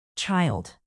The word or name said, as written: child